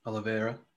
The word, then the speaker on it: Oliveira: How do you say his name? – Oliveira.